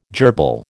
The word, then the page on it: jerbil: American English